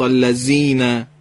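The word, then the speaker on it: الذين: نوک زبان میخوره دندونای بالا و حرف بله. زال تلفظ میشه بله. پس نباید زال رو مثل زه زنبور تو فارسی بگیم بله. بگیم صراط اللذین، بعد بگیم صراط اللذین، صراط اللذین.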